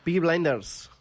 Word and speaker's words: Peaky Blinders: Peaky Blinders. – Molt bé. Tu tens el nivell d'anglès més potent que jo. – Acabo d'inventar, Peaky Blinders. – Ah, vale. S'estrena... es deia així... Peaky Blinders.